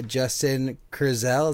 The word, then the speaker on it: Justin Kurzel: We have with us today Justin Kurzel.